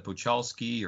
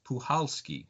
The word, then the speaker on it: Puchalski: because I was pronouncing it Puchalski or Puchalski. And they said the C is silent. It's Puchalski. So, okay. So I've had to, you know, kind of train myself to remember the C was silent and do that.